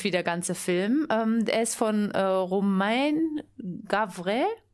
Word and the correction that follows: Gavras: wenn das Gavras, Gavras wird er ausgesprochen, genau, ähm, weil es sind Profischauspieler, aber ganz viele Einwohner der Siedlung haben sich als Statisten zur Verfügung gestellt.